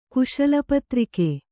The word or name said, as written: ಕುಶಲಪತ್ರಿಕೆ